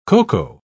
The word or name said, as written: coco